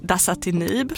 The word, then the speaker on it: dasatinib: D står då för dasatinib.